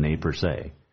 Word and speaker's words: Nez Perce: On this date in 1877, at the Big Hole River, Colonel John Gibbon murders a whole bunch of Nez Perces, also known as Nez Perce, but the correct pronunciation is the Nez Perce.